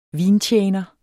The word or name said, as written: vintjener